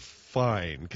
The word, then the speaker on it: Fiennes: What's this Rafe Fine? Can somebody help me with that? What is that? That's not how you spell it, man. You spell it R-A-L-P-H. And if you say Ralph, people look at you like, ooh, don't you know? No, I don't know. What's wrong with Ralph Fiennes or Fine or however the hell you want to be?